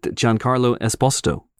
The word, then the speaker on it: Giancarlo Esposito: And don't forget to get Giancarlo Esposito, which is yeah.